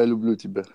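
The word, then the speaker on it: Lublu Tibet (люблю тебя): How do you say I love you again? I know it's Lublu. What's the rest of the phrase? – Yeah, Lublu Tibet. Yeah, Lublu.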